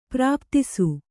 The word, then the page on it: ಪ್ರಾಪ್ತಿಸು: Kannada